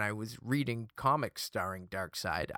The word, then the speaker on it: Darkseid: And they, of course, said it's pronounced Darkseid. Like the dark side of the force, for example.